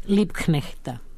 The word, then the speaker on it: Liebknechta: Ona piše sonječki ženi, to jest mladoj supruci Karla, hoćemo li ga izgovoriti, ja sam ga jedva izgovorila, ajmo još jednom, Liebknechta. Mislim da sam dobra sada.